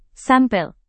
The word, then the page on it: sampel: indonesian